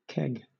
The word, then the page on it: keg: British English